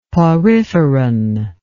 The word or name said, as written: poriferan